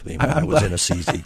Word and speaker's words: Assisi: Yeah, that's how you pronounce that. – It is, and do you know that for sure? Just like, or you just? – I had a bishop tell me that when I was in Assisi.